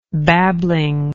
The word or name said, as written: babblingly